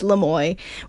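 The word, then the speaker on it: Lamoille: And for the longest time, I was calling it Lamolly because it's spelled L-A, capital L-A, and then capital Molly. So then I was like, okay, cool, we're going to Lamolly. And in fact, no, it's Lamoy, which is a very nice hiking trail, I will say.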